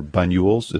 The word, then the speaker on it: Banyuls: Banyuls. It's spelled B as in boy, A-N-Y-U-L-S.